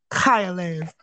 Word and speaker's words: Chayalev: and I say pretend that someone shot, like, some cum in the back of your throat, and you don't want to swallow it, you just want to get it out, so you go, like, that's how you pronounce Chaya, Chayalev.